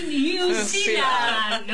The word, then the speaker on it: New Zealand: Good morning, New Zealand.